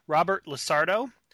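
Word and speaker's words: Robert LaSardo: Manny Ruiz, portrayed by, and forgive me if I mispronounce this, Robert Lissardo. – That's how you pronounce it.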